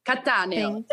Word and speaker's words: Catania: CamillaCatanio_. Catania. Italian.